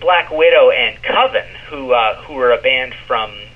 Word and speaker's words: Coven: It's pronounced Coven, man. What else could it be pronounced? – Coven, that's the proper pronunciation. – No, Coven sounds like oven, man, and that's just, it doesn't work.